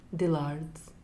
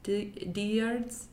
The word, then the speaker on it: Dillard's: How is it pronounced again? – Dillard's. – Diards? – Diards. – It's a double L. I'm confused.